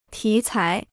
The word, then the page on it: 题材: tí cái